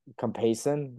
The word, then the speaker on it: Compeyson: First of all, it is Compeyson. That's how it's spelled, and that's how it's pronounced, but it's Cumbersome. – Compeyson. So it's not cumbersome.